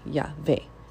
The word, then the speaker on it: Yahweh: And you start the next syllable with the v. Um, and so typically, like when you look at so many Hebrew words following that principle, it makes sense to be Yahweh.